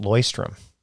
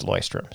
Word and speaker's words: Leuchtturm: By the way, I learned that it's pronounced Loistrum.